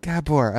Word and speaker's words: Gabor: Did I say it right, Gabor? – Gabor, I'm sorry.